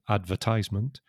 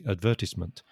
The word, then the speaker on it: advertisement: It's got two pronunciations. neither of them is wrong, right or wrong. It's just your personal preference. So some people say advertisement, mm-hmm. and some people say advertisement, and mm. they're both right.